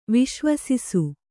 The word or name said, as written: ವಿಶ್ವಸಿಸು